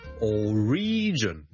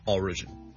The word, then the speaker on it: origin: number two 呢，如果读成 origin 呢就会怪怪地了，因为个重音其实 origin O-R-I-G-I-N 个来源呢，我哋个重音是第一个音节的，所以 single origin origin。